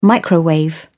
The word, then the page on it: microwave: British English